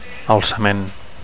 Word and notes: alcament: Catalan

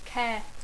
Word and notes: care: English

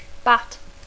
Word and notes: bat: English

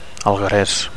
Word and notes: algueres: Catalan

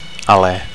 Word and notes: ale: Catalan

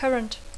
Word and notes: current: English

